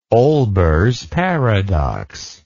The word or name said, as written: Olbers paradox